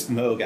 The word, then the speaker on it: Moog: I believe it's Moog, actually. – Oh, is it? – Yeah, it's two O's, but I believe it's pronounced Moog.